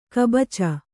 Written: ಕೈಬಾಚಿ

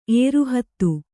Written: ಏರಹೊತ್ತು

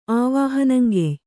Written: ಆವಾಹನಂಗೆಯ್

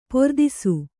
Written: ಪೊರ್ದಿಸು

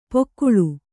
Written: ಪೊಕ್ಕುಳು

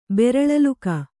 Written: ಬೆರಳಲುಕ

